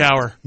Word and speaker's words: Cowher: I thought you said Cowherd. Yeah. – No. – That's why I was like, no, that's not right. But I didn't want to say anything. – I definitely added an extra syllable in there. Or a space where it didn't need to be a space.